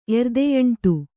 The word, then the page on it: ಎರ್ದೆಯಂಟು: kannada